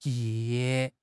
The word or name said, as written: ye4